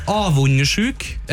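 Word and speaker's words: avundsjuk: Og det uttales avundersjuk, og det betyr at du...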